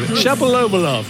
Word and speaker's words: Shapovalov: It's like Denis Shapovalov. Shapovalov. That's it. Shapovalov gets it all wrong.